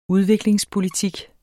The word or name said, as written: udviklingspolitik